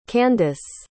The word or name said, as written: candiss